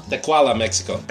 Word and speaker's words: Tequila: Brisbane, Australia, Innsbruck, Australia, Tecuahuala, how do you say that? Tecuala, Mexico. – Let me see it. – Tequila? – Tequila. – Tequila, Mexico.